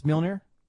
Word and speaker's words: Mjolnir: I've never figured out how to pronounce Mjolnir. – It. It's Mjolnir? Okay. The J kind of throws it off.